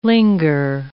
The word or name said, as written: linger